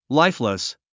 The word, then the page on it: lifeless: láifləs; ライフレス